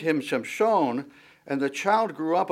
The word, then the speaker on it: Shimshon: or Shimshon would probably be the way that you pronounce it, which actually means like the sun, like the sun.